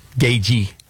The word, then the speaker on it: Gaethje: So Joe Rogan is doing an interview with UFC fighter Justin Gaethje. Gagey is how you pronounce it. The TH is silent.